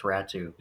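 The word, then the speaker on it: Räty: It's apparently pronounced. – It's actually pronounced Ratu. – Yeah, it's pronounced Atu Ratu. – Oh, sorry. – I know. – Sorry, Ratu. It's weird. Sorry, it's not weird. It's a different language.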